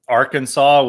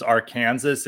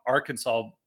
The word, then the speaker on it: Arkansas: It's like Arkansas, which you would probably pronounce as Arkansas if you didn't know. But it's actually, there's like laws in Arkansas, like outlawing calling it Arkansas.